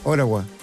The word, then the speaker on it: Ottawa: O sea, ¿no se dice Ottawa? ¿Cómo se dice? – Ottawa. – Ottawa. – Ottawa. Oh, yes.